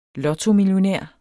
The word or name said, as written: lotto